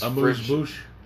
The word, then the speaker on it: amuse-bouche: I'm reading these off thing because this is this amuse-bouche.